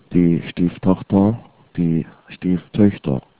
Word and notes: stieftochter: german

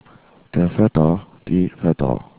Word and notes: Vetter: German

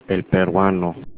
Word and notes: peruano: Spanish

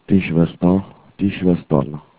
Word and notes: schwester: german

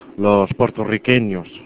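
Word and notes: puertorriqueños: spanish

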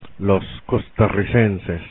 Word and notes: costarricenses: Spanish

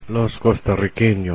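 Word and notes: costarriqueños: Spanish

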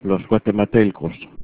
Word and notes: guatematelcos: Spanish

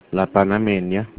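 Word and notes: panameña: Spanish

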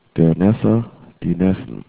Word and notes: neffe: german

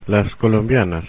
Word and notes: colombianas: Spanish

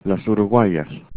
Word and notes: uruguayas: spanish

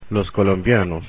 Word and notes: colombianos: Spanish

